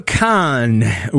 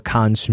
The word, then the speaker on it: Kahan: Because, fun fact, if you've never actually read this name on like a streaming service, right, you maybe listen to Noah Kahan's music. It's K-A-H-A-N. – Which is clearly Noah Kahan. – Yes, right? Kahan. Phonetically, it's Kahan.